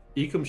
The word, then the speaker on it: Ekim: E-K-I-M. Ekim Schneider. – What's that? – Ekim, I hardly know him. – E-K-I-M. Ekim. Ekim Schneider. – How do you spell the last name? What? – Yeah. – S-C-H-N-E-I-D-E-R.